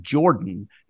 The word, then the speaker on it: Jordan: In that region, that little pocket of the South, the same name, which is spelled the same way as Michael Jordan, is pronounced Jordan.